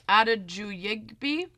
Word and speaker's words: Adejuyigbe: His name is Demi Adejugibe. I totally butchered his last name, but search Demi. – I would have nailed it. – Yeah. Search Demi on YouTube. It's spelled Adejuyigbe. Adejuyigbe.